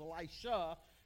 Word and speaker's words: Elisha: Elijah, okay, make sure you get those pronunciations right, because Elisha is different from Elijah.